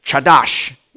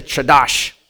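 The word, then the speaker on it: chadash (חדש): It's chadash. It's a chadash. If you spit in the person's back of the neck, in the person in front of you, you've said it correctly.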